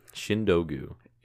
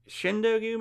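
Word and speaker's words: Chindogu: Shindogu. Shindogu, maybe? See, it's spelled like shin. – Okay. – Dog, you.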